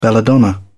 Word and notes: belladonna: British English

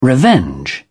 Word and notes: revenge: British English

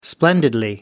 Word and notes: splendidly: British English

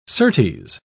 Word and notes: certes: British English

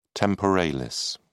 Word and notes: temporalis: British English

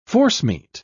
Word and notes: forcemeat: British English

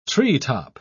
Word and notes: treetop: British English

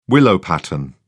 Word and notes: willow-pattern: British English

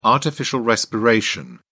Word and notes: artificial respiration: British English

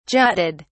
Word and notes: jutted: British English

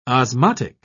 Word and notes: osmotic: British English

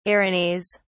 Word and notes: erinys: British English

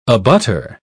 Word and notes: abutter: British English

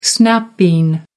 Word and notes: snap bean: American English

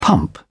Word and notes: pump: British English

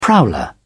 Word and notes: prowler: British English

